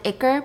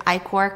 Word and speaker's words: ichor: Uranus's ichor is, I know some people pronounce it ichor, but I'm pretty sure it's ichor because I went to like all these like Greek pronunciation websites.